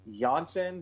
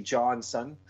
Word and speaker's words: Janssen: This is one where he hits they say Janssen, but I just see Johnson. – Yeah. – That's me being American. – That's the sweetest pronunciation. It's like Jakob and Jacob.